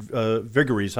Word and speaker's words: Viguerie: Richard Viguerie, how you pronounce it, right? – Yeah.